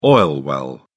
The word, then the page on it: oil well: British English